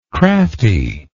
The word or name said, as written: craftiness